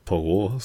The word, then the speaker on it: Poirot: dieser be besagte Pyro ist halt, nicht Pyro. wie spricht man es denn aus? Poirot? – Poirot, sagen Poirot. wir einfach Poirot.